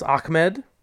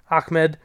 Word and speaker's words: Ahmed: I was impressed by the back for the Dolphins, Ahmed. And he came out of nowhere. That's the way the broadcast team was pronouncing it. I thought it was Ahmed at first, but I'll go with it. Ahmed played pretty darn well.